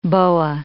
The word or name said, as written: boa